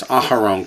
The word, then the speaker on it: Aharon: How is that different from Aaron? Aaron is Aharon, correct. There's an extra syllable.